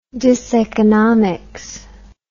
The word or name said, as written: diseconomics